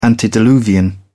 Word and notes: antediluvian: British English

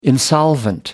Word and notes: insolvent: American English

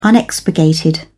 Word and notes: unexpurgated: British English

